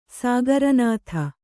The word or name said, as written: ಸಾಗರನಾಥ